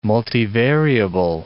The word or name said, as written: multivariable